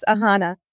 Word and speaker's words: Aahana: It's Aahana.